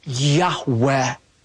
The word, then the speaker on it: Yahweh: languages feel it's pronounced something like a breathy Yahweh.